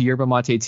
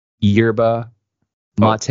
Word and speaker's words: yerba mate: So he got me into yerba mate tea. – How do you spell that? – Y-E-R-B-A, yerba mate. – Oh, how it sounds. – Yeah, M-A-T-E, yerba mate.